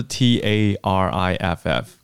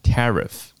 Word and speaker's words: tariff: 好，那所以它的发音并不是我们很直觉，它是 T A R I F F，可是念成 tariff 啊 tariff。